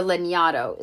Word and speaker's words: Lanyadoo: So for instance, Jessica Lagnado, or Lagnado is how you pronounce her name, actually.